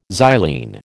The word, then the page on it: xylene: American English